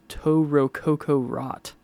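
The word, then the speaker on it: To Rococo Rot: And then we have an electronic band or group called Toe Rococo Rot. That's how it says to pronounce it on the CD cover.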